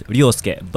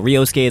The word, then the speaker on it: Ryosuke: Ryosuke is actually my... Oh, if you actually call it through Japanese, it's Ryosuke. But, you know, Ryosuke is like the best English kind of equivalent to it.